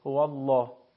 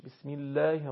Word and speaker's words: الله: یعنی فقط همین رو بدونید، هر زمانی قبل از کلمه الله اگر ضمه و فتحه باشه الله به صورت لامش به صورت درشت تلفظ میشه، هو الله. ولی اگر زمانی که قبل کلمه الله کسره باشه، مثل همین ذکر شریف بسم الله الرحمن الرحیم، در این صورت الف مدی به صورت در واقع کم حجم تلفظ میشه، بسم الله الرحمن الرحیم.